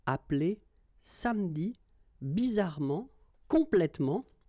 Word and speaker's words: appeler: Okay, same thing here, appeler, but then you get appeler, appeler, and then samedi.